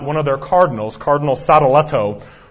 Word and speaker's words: Sadoleto: And he wrote his famous reply to Sadoleto. I would encourage you to Google that. Sadoleto is spelled S-A-D-O-L-E-T-O, Sadoleto, which still stands as one of the greatest summaries of Reformation biblical truth ever written.